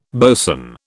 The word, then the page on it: boson: British English